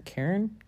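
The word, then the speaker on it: cairn: this is just, it's called a Karen? Karen? I don't know. I can't pronounce it right. Anyway, it sounds just like the name Karen,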